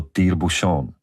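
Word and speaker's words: tire-bouchon: Správně je to Týrbušen a znamená to vývrtku.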